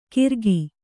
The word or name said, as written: ಗಿರಕಿ